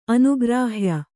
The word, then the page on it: ಅನುಗ್ರಾಹ್ಯ: anugrāhya